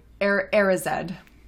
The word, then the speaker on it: Erised: I mean, seeing what affects this Mirror of Desire, Ezred, is that how do you pronounce this? Ezred? – Erised. – Erised, sure. Has on Harry, and it's strongly implied has on Dumbledore too, is painful to see.